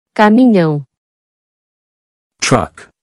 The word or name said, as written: Caminhão